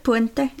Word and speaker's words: Puente: Puente, is that how you say it? – Puente. – I'll go with it.